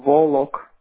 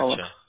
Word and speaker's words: Volok: Volok. – How you spell that? W-A-L-O-C-K? Or 2-L? – V-O-V-O-L-O-K. – Oh, Volok. Okay, Volok. Gotcha. – Volok.